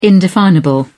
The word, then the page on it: indefinable: British English